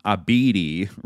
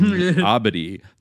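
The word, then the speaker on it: Abedi: The former colleague says Skyling pronounced the name Abidi rather than Abadi, the correct pronunciation.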